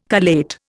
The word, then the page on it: collate: Indian